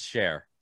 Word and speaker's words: Cher: Like Sonny and Shear? – It's Cher. It's definitely Cher. – It's definitely Cher. – You know how loud my fiance is going to laugh when she hears this? Because I mispronounce fucking everything. – How did you spell it? – C-H-E-R. – Yeah, that's not even spelt Sheer. – Sheer would be S-H-E-R-E.